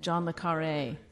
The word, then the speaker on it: John Le Carré: James or John Le Carré. Did I pronounce those names? – John Le Carré. – Thank you.